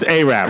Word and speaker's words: Arab: It's pronounced Arab. – Correct. Well, at least in part of the town. – No, that's how you pronounce it, because originally it was Arad, but the people that created the town were illiterate and they wrote the D backwards.